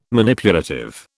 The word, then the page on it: Manipulative: British English